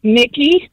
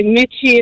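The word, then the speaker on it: Michie: So it's pronounced to be pronounced correctly, it's actually Mickey, but a lot of people say Mitchie. It's M-I-C-H-I-E. – Ah, so it can be misconstrued. Like Kelce looks like K-E-L-C-E.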